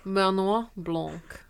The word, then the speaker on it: Benoit Blanc: Ah when Detective Benoit Blanc exactly uh, joins the case, everyone begins to dance and sing.